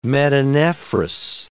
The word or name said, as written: metanephros